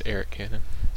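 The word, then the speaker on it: Arik Cannon: Eric. It's Eric Cannon. – See, the way it's spelled, I want to say Arik. – Yeah. – Yeah. Okay. Sorry, I know musicians named Arik.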